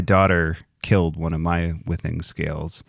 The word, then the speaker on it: Withings: It has lasted a very long time, and it gives me the opportunity to let everybody know that it's pronounced Withings, because not one podcaster in my feed pronounces this right.